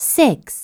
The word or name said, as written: 6